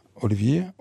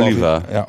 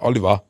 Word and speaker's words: Olivier: Sagen wir sehr pädagogisch bei Twitter reinschrieb, aber der sagte, ey, der wird nicht französisch ausgesprochen, was der Vorname ja nahelegt. – Der Vorname ist nochmal wie? – Olivier? Mhm. Olivier, ja, egal. Aber Oliver. – Oliver.